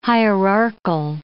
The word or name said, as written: hierarchal